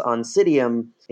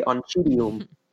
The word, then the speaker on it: Oncidium: So for example, the orchid genus Oncidium, in English, C-I you pronounce like an S. Why, I have no idea. But it's actually Oncidium, which would be the Latin way to say it.